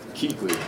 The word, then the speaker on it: Keekly: Keekly. It's like K-E-E-K-L-Y, Keekly. – Thank you.